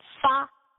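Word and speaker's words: fa (发): because the number 8 is pronounced as ba, and that has a very similar pronunciation to the word fa, which means being rich and being prosperous.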